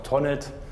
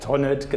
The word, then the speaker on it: Tonet: Also Tonet. – Also wir im Rheintal sagen ganz einfach Tonet. – Tonet. – Tonet, genau. Wie man halt hier so spricht.